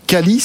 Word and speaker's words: Qualees: Vous êtes euh, président de Calis, c'est comme ça qu'on prononce Qualis. Ou, Qualis, d'accord.